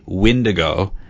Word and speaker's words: Windigo: One is the Windigo, and the other is the Wendigo. Oh. One is spelled W-I-N, and one is spelled W-E-N.